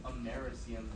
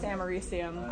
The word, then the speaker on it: americium: I thought it was like americium because like there's like Einstein. – It's named after America, but it's pronounced americium. I know. Americium.